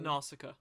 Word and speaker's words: Nausicaa: Um, and I, I, can never pronounce the, the first word of it. The N- Nakasa? – Nausicaa. Nausicaa of the Valley of the Wind.